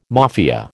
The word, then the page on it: Mafia: American English